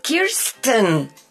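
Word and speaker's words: Kirstjen: Es Kirsten. – Oh, Kirsten Nielsen. – Sí, porque no es Kristen. – Bueno, la J es como... – Kirsten. – La J se pronuncia.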